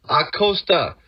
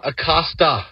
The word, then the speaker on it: Acosta: Just wanted to ask you, like in the word nachos in Spanish, the O, what kind of sound does it have? The A, what kind of sound does it have? Nachos. That's why you pronounce it Lucho Acosta. Acosta, not Acosta.